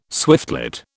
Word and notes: swiftlet: British English

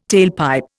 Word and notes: tailpipe: Indian

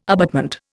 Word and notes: abutment: Indian

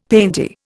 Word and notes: painty: Indian

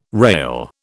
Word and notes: rale: British English

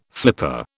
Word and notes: flipper: British English

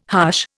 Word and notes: hush: Indian

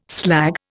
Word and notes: slag: Indian

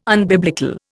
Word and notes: unbiblical: Indian

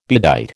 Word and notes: bedight: American English

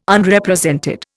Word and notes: Unrepresented: Indian

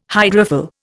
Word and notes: hydrophil: Indian

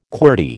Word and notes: qwerty: American English

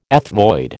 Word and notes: ethmoid: American English

